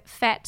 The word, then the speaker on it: Fete: So Fette spelled F-E-T-E. And pronounced fet, not fete.